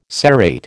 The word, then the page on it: serrate: American English